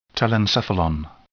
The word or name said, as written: telencephalon